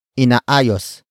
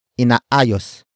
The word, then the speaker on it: inaayos: Inaayos na ang bubong. Inaayos na ang bubong. The first word is inaayos. Inaayos. Inaayos. Inaayos is spelled as I-N-A-A-Y-O-S.